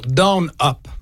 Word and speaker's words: DNUP: En commençant avec une nouveauté qui s'appelle, il est difficile à prononcer, ça s'écrit D-N-U-P, OK? D-N-U-P pour Down Up, si on veut.